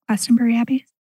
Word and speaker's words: Glastonbury Abbey: Glastonbury Abbey? Am I saying that right? – Yeah. – I think so.